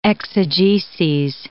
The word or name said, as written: exegeses